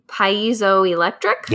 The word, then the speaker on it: piezoelectric: It's because they're, I don't know how to pronounce this word, piezoelectric? – Yep.